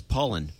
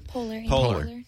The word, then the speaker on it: Poehler: Participants include Madonna, Shaquille, comedian from Saturday Night Live's Amy Poehler. – I thought it was Paulin. – How do you pronounce that? – Poehler.